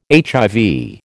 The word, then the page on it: HIV: American English